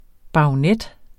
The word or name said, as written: baronet